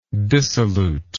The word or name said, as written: dissoluteness